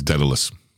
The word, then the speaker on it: Daedalus: But it is to believe that the phrase originated from the story of Dedicles. I totally don't know how to pronounce his name. – I think it's Daedalus. – Daedalus and Icarus from Greek mythology.